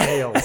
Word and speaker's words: Ailes: I said alias earlier, but you guys already auto-corrected me. – Yeah, pretty sure it's Ailes. – Pretty sure it's Ailes.